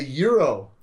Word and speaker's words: gyros: That is a euro.